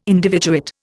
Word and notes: individuate: Indian

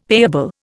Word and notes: payable: Indian

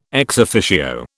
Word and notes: ex officio: British English

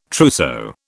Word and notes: trousseau: British English

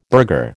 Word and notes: burgher: American English

